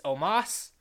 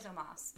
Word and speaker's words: Omos: And it's Omos, I believe. – Oh, you're right. I think it is Omos.